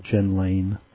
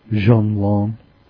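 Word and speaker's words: Jenlain: It's what you'd want to call Jen Lane, but I believe the correct pronunciation is Jean Lam.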